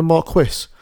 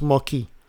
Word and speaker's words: Marquis: I keep kept calling him a Marquis. It's, well, it's, well, it's, French. spelled Marquis, but it is pronounced Marquis.